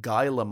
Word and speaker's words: Gila: It's spelt G-I-L-A. And one of I believe, a do-go-on listener actually corrected my pronunciation because I was saying Gila monster. And they went, Gila. Like that. – Okay. Yeah, I don't know how you got Gila from G-I-L-A.